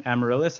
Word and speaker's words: Amaryllis: And the other one, Amaryllis, am I pronouncing it right? – Yes. – Yes, nailed it.